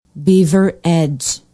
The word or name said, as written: beaver edge